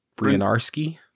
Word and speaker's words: Bryniarski: The um, Bryniarski.